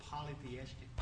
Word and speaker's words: polytheistic: You pronounce that word polytheistic. – Polytheistic. – And mm-hmm.